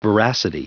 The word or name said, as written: veracity